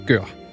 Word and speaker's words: gør: Du sagde jo ikke en lyd, du sagde lyden af en hund, der gør, og jeg tror faktisk, det skal udtales gør.